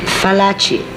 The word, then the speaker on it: Fallaci: Fallaci, F like flower. Not V like Venice.